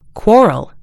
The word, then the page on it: quarrel: American English